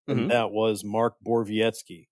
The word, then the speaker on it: Borowiecki: That's coming directly from Mark Borowiecki's mouth. – Yes. – So there may be others out there who want to interpret it differently and say, oh, no, this is how you say it. But the man himself told me, Mark Borowiecki.